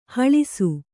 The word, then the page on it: ಹೊಳೆಸು: hoḷesu; Kannada